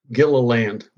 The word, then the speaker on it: Gilliland: Gilliland.